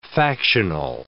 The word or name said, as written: factional